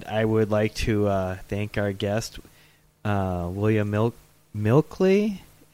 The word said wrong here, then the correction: Meikle: Am I saying that, James? James, correct me. What's his Scottish pronunciation? – I'm not sure. My memory's been wiped. – Oh, he's going to kill us. It is Meikle.